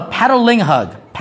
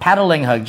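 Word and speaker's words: Patalinghug: No, Pataling-Hug. Pataling-Hug, just like it's spelled.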